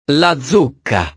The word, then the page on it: la zucca: italiano